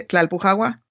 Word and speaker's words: Tlalpujahua: A ver, repite otra vez el nombre del pueblo donde nació mi madre, Tlalpujahua. No sale. – Tlalpujahua. – Cerca, estuvo cerca.